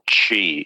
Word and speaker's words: Qi: So the first generation wireless chargers use a format called Qi.